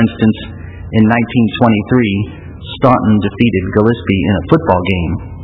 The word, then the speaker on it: Gillespie: Staunton is the town I came here from in Illinois, and Gillespie, notice, I pronounced it Gillespie. It rhymes with crispy, and most people say Gillespie, and that's how you know if they are from Gillespie or not, whether they say it correctly.